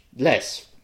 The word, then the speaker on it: le: Reportez-vous à icônes orange de fonctionnalités pour connaître le Les. Los slipped into Spanish there. – It's not les, it's le. You don't le. Pronounce the S.